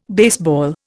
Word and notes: baseball: Indian

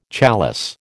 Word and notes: chalice: American English

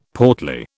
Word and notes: portly: British English

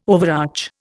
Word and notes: overarch: Indian

